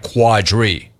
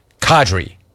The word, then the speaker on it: Kadri: Kadri wanted to make sure that you knew it was not Quadri. Kadri.